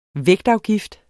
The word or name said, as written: vægtafgift